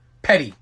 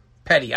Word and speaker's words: Petty: He drives for the Richard Perry team. Is that how you say it? I just fucked that up. All right, NFL. I don't know if we have time to cover this. Petty. Thanks, Duff. Petty.